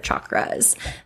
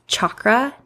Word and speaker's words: chakra: This is in connection to our ear chakras. By the way, it's pronounced chakra, not chakra, from what I've learned.